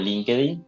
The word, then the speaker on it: LinkedIn: Eh, estoy empezando a adaptarme con LinkedIn eh, o LinkedIn, como la pronunciación.